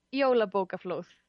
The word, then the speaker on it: Jólabókaflóð: Is it Jólabókaflóð or how do you pronounce that? – Yeah, Jólabókaflóð.